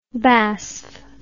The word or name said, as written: basf